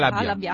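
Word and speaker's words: Halabja: Usando armi chimiche, il regime iracheno stermina 5.000 curdi della cittadina di, Ar- di, Al- di Al- Albia. Scusa, non riuscivo a pronunciare Albia. – Non è Al- Halabia. Halabia. Halabia.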